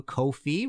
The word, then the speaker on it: Ko-fi: If you like this show and want to support future episodes, episodes, you can go to Ko-fi, rhymes with no-fi, .com, / Martin Popoff, hit that red support button, and buy me a coffee or a pint.